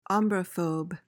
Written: ombrophobe

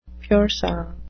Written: pur sang

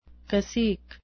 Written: cacique

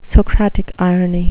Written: socratic irony

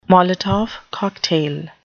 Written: molotov cocktail

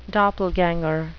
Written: doppelganger

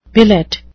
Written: billet

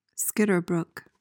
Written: skitterbrook

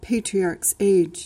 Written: patriarchs age

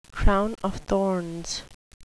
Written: crown of thorns

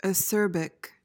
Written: acerbic

